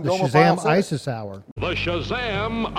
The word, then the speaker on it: Shazam: And you do have to say it like that. It's Shazam. It's Shazam.